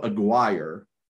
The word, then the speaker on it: Aguirre: Popo Aguirre, I guess is how you say it. It's A-G-U-I-R-R-E.